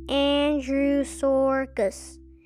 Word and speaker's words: Andrewsarchus: You say it like this Andrewsarchus.